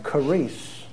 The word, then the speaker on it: charis: pronounced something like charis.